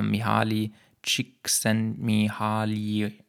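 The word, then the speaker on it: Mihály Csíkszentmihályi: Aber wenn du diesen Namen googelst, dann würde das ungefähr ausgesprochen so aussehen: äh, Mihaly Csikszentmihalyi.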